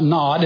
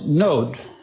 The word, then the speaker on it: Nod: In Hebrew, it's not Nod, it's Nod. It's pronounced long O.